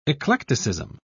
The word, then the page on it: eclecticism: British English